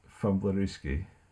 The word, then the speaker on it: Fumblerooski: Did I pronounce that right? – Fumblerooski. Yeah.